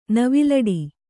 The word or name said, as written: ನವಿಲಾಟ